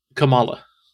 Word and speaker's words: Kamala: Is it Kamala or Kamala? – I thought it was Kamala. – Or can you not hear the difference of how I say it? – It's Kamala. – Okay, Kamala.